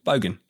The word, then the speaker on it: bogan: Yeah, no, bogus. – Really. – No. I said it wrong, didn't I? What was it? – Bogan. – Bogan.